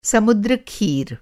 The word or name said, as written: समुद्र खीर